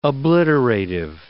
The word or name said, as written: obliterative